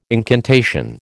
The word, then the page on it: incantation: American English